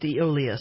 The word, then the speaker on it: Aeolus: By the way, it's pronounced Aeolus.